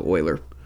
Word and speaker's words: Euler: It's spelled the exact same way, but like pronounced, it's like O-Y-L-E-R. – Okay. – So Euler.